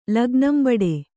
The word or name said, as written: ಲಗ್ನಂಬಡೆ